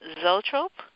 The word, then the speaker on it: Zoetrope: You worked on several wine and food ventures, and then transitioned over to Coppola's film studio, American Zoetrope. Is that how you pronounce it? – That's correct.